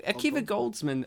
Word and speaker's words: Akiva Goldsman: Akiva? – Akiva Goldsman, A Beautiful Mind, and stars Colin Farrell, spelled with just the one L.